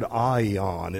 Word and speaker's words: aion: And the reason for that is the word here, eternal, is the Greek word aion. It's spelled A-I-O-N. It is aion. It's A-I-O-N. Aion.